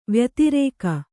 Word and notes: ವ್ಯತಿರೇಕ: vyatirēka

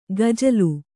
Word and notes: ಗಜಲು: Kannada; gajalu

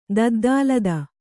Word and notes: ದದ್ದಾಲದ: Kannada

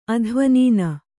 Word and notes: ಅಧ್ವನೀನ: adhvanīna

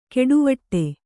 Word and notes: ಕೆಡುವಟ್ಟೆ: Kannada; keḍuvaṭṭe

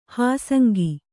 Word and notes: ಹಾಸಂಗಿ: hāsangi

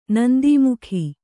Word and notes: ನಂದೀಮುಖಿ: nandīmukhi

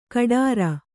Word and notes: ಕಡಾರ: kaḍāra

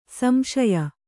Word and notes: ಸಂಶಯಿ: samśayi

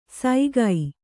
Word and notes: ಸೈಗೈ: saigai

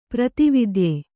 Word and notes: ಪ್ರೇತವಿದ್ಯೆ: prēta vidye

